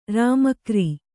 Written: ರಾಮಕ್ರೀ

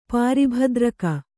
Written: ಪಾರಿಭದ್ರಕ

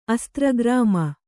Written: ಅಸ್ತ್ರಗ್ರಾಮ

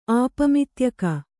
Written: ಆಪಮಿತ್ಯಕ

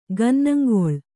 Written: ಗನ್ನಂಗೊಳ್

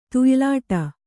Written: ತುಯ್ಲಾಟ

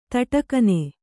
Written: ಥಟಕ್ಕನೆ

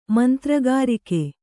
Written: ಮಂತ್ರಗಾರಿಕೆ

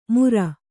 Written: ಮುರ

